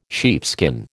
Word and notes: sheepskin: American English

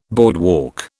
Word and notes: boardwalk: British English

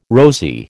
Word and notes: rosy: American English

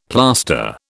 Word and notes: plaster: British English

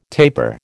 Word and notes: tapir: American English

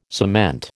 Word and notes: cement: American English

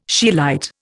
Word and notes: scheelite: Indian

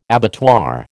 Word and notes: abattoir: American English

